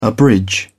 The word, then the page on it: abridge: British English